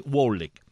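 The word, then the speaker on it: Kwolek: Stephanie Wolek, o Wolek, así se pronuncia, Stephanie Wolek.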